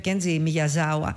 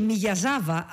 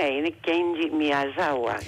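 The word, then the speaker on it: Κέντζι Μιγιαζάουα (宮沢): που κάποιοι τον γράφουν και Μιγιαζάβα, αλλά δεν είναι, γιατί από ό,τι διάβασα είναι Μιγιαζάουα, δεν προφέρεται. – Ναι, είναι αυτό το «βα» το οποίο έχουμε συνηθίσει, ναι. – Από το «γουά» το αγγλικό, αλλά όμως ναι, είναι Κέντζι Μιγιαζάουα.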